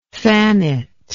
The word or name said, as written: thanet